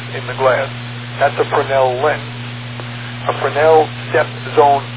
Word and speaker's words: Fresnel: Okay, the people who know French pronounce it Fresnel. Although it's spelled Fresnel, they pronounce it Fresnel.